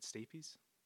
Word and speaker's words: stapes: And so the stapes, I hope I'm saying it right, stapes? It's S-T-A-P-E-S? – It sounds about right.